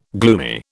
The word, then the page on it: gloomy: British English